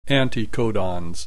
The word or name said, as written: anticodons